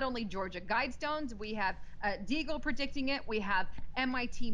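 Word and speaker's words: Deagel: You and I talk about Daigle, or is it Deagle or Daigle? I always Deagle. – Deagle. I always say it wrong.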